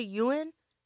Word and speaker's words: yuan: Yuan? – The yen, the YUAN.